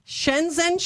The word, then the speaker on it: Shenzhen: Shenzhen, China.